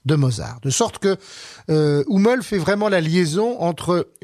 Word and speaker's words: Hummel: Et donc j'ai choisi de vous parler d'un compositeur assez peu connu, qui pourtant me semble-t-il très intéressant, et qui s'appelle Johann Nepomuk Hummel, ou Hummel si vous le prononcez à la française.